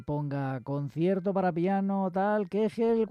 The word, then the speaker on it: Köchel: Kegel con K, y se escribe Köchel, pero ellos dicen Kegel, ya sabéis que son muy, muy duros ellos, los alemanes.